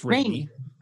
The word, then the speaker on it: Raimi: So we watched Drag Me to Hell, which is Sam Raimi's Rami, how do you pronounce that guy's name? – I think it's Raimi. – That's what I thought. Sounded wrong when I said it.